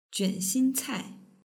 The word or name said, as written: juanxincai